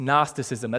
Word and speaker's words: Gnosticism: Gnosticism, that's a soft G, if anyone's wondering.